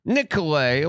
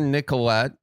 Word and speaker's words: Nicolet: Nicolet or Nicolette, if you're not about the silent T's.